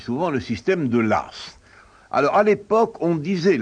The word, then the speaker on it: Law: D'abord, pourquoi prononce-t-on l'As? L'eau, c'est L-A-W.